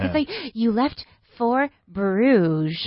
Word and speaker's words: Bruges: And we left for Bruges. How do you say Brujas? It's very difficult for me. – Bruges. – Bruges. – Bruges.